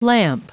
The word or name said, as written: lamp